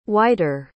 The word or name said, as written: whiter